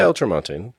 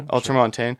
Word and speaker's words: ultramontane: The ultramontane? Is that? How bad of a pronunciation is that? – Yeah, ultramontane. Yeah, ultramontane.